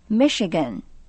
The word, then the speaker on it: Michigan: 接着我们来学一个美国的州名， Michigan， M-I-C-H-I-G-A-N， Michigan。再说一遍， Michigan， M-I-C-H-I-G-A-N， Michigan。根据它的发音，我想你一定能猜得到，这就是密西根州。那么以密西根州的名字来命名的大街该怎么说呢？ – Michigan Street。